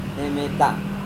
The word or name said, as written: nemeta